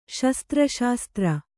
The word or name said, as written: ಶಸ್ತ್ರಶಾಸ್ತ್ರ